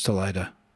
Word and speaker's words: Salida: It's pronounced Salida, I realized, I learned.